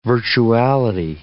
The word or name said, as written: virtuality